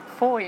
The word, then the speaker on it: Fowey: And Foy, you might not know, it's got a strange spelling. It's spelled F-O-W-E-Y. And doesn't look like you pronounce it as Foy, but we rhyme it with joy.